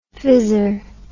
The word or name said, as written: fizzer